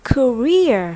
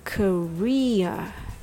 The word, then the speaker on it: career: Now, career has three syllables and the stress is on re. Career.